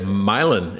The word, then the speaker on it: Milan: Yep, Milan. It's Milan. just like the song. This land is your land.